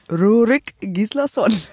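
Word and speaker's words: Rúrik Gíslason: Ich bin sehr dankbar für diese Homepages, auf denen man das nachgucken kann, wie die sich aussprechen. Und ich kann nur sagen, Rurik Gislason. – Genau wie du meintest.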